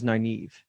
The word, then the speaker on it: Nynaeve: How do you pronounce it? – Nynaeve. I say my audiobook says Nynaeve.